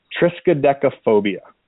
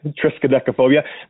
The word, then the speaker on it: Triskaidekaphobia: So Triskaidekaphobia. – I'm scared of that name. – Yeah, Triskaidekaphobia.